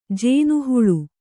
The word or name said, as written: ಜೇನುಹುಳು